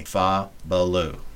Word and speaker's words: Fabaloo: It took me a long, long time to learn how to pronounce this, so yeah. – Go with me. Fabaloo. – Oh, Fabaloo. Gotcha. I thought Fabaloo. You said 50 Lou.